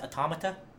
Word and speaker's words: Automata: Did you know it's apparently pronounced Automata? – Automata? – Yeah, because it's like an automaton.